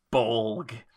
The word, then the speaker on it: Bolg: The rivalry of the last two films now together is Legolas versus Bolg. That's B-O-L-G, baby. Emphasis on the G.